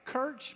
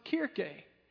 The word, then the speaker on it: Kirche: It looks like Kirch, but it's actually, I've learned recently, pronounced Kirche.